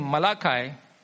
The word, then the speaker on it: Malachi: And the Hebrew name Malachi, in Hebrew actually it's Malachi. So let me say Malachi. In Malayalam also it's Malachi. That's the right pronunciation.